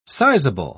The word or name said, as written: sizeable